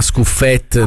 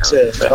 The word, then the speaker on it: Scuffet: Gli dici si chiama Scuffet, non è francese e non si chiama Scuffet. – Scuffet, oh, è friulano.